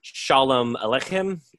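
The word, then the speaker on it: Sholem Aleichem: So in 1959, he went on tour in Paris with a group of other artists for the 100th celebration of Shalom Aleichem.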